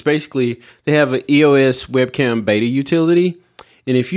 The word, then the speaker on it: EOS: Is that how you say it? EOS? – Yeah, EOS. Yeah.